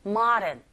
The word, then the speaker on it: modern: 모던이라고 발음하기보단 그렇게 들린다라기보다는 O의 강세가 갑니다. 그럴 때 아에 가깝게 modern 그리고 드 발음 자체가 모음과 모음 사이에 껴있죠. 그래서 드보다는 으르에 가깝게 modern, modern than it looks 갈게요.